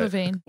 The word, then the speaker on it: Levine: Levine.